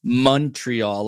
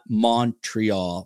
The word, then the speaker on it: Montreal: If you want to sound like a native, you pronounce it Montreal, like money, honey, not Montreal, Montreal, Jersey style.